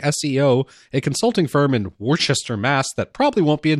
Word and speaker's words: Worcester: Yes, I do believe it's pronounced Worcester.